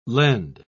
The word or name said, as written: lend